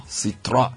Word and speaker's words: Citroën: Well, beginning 4th July, all Peugeot and Citroën, is that how it's pronounced? – Citroën. – owners can visit any Silver Star Auto Workshop in Accra. It's almost like French. You have to speak it with some...